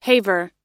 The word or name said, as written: haver